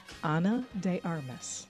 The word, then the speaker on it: Ana de Armas: Rihanna, Ana de Armas. I don't know why I can't. Ana de Armas. Mm-hmm. Did I say that right? Mm-hmm. That sounds better.